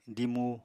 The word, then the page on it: dimu: apatani